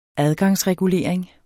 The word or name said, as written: adgangsregulering